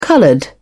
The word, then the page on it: colored: British English